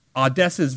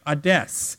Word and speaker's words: Adès: And it's even funnier because wherever Adès goes in many European countries, his name is pronounced more like Hades, like Hades.